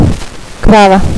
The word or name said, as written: krava